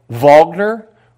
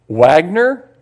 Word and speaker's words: Wagner: By the way, if you ever pronounce German words, when it says W, it's a V. I remember my first year of music theory, I called Wagner, Wagner.